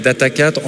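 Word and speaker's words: Data4: Donc moi, je prononce en fait Data 4, en fait, avec le... puisqu'on est très local, on dit Data Quattro, Data 4, Data Sphere.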